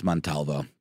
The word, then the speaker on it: Montalvo: I said Amantavallo, and it's Montalvo. But I just I switched up the V and the A.